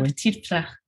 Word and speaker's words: Ma Petite Fleur: That, by the way, was the reason that I used my Ma Petite Fleur. Am I pronouncing that correctly, by the way? I love it. Ma Petite Fleur. Okay. Très bien. Perfect.